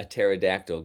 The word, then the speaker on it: pterodactyl: Why can't you hear a pterodactyl go to the bathroom? – Why? – Because the P is silent.